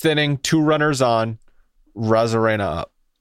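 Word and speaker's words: Arozarena: So you took off the A, and it's not. It's a Rosarena. That's how you say it.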